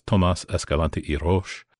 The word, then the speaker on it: Tomas Escalante y Roche: Now, there is another young man that comes into the orbit of Nellie Sawyer, and his name is Tomas Escalante y Roche. That's a heck of a name. – Yeah, and you pronounced it perfectly.